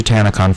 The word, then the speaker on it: TanaCon: So it's actually TanaCon, folks.